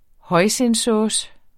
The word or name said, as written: hoisinsauce